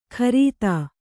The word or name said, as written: ಕರೀತ